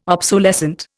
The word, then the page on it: obsolescent: Indian